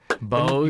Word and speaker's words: Bose: Bose. I didn't tell him how to pronounce it, but yeah. – No, he didn't tell me how to pronounce it. Bose. – You got to have the noise canceling. – I thought it was from Idaho. That's why. – Oh, Boise.